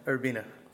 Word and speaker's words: Urbina: Urbina? Yeah, man. Urbina.